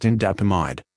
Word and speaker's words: indapamide: Sustained-release tablets containing indapamide, pronounced indapamide.